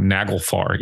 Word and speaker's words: Naglfar: I'm going to say this band's name wrong. Naglfar, even though I've seen it my whole life. Does that sound right? – You got it.